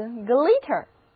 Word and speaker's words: glitter: little 的意思是混乱的，杂乱的，那得到了新单词 glitter，注意元音字母 i 发音是 e， glitter。